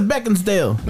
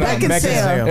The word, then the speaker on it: Beckinsale: You say her name right. I always thought it was Beckinsdale. – No, it's no, back in sale.